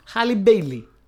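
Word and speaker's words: Halle Bailey: Ε, επίσης, ε, μιας και γινάμε έτσι, να πω ότι η υπέροχη Χέιλι Bailey, Χάλι Μπέιλι, Χάλι Μπέιλι, το προφέρω σωστά. Δεν έχω ιδέα. – Χάλι Μπέιλι.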